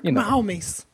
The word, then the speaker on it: Mahomes: And I think Texas Tech, their offense, Patrick Mahomes, I believe it's how you pronounce his name. – That is how Mac Brown would pronounce it. – It's got a little Spanish in it. – Patrick Mahomes.